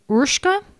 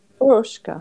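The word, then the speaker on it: Urška: According to a friend on the Velo Club Slack, her name is properly pronounced Urshka. Like, Urshka. – If you like spell it out how it sounds, O-O-R-S-H-K-A.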